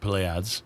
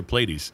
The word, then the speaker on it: Pleiades: The French would say it Pleiades. – Okay. – And us Americans say Pleiades.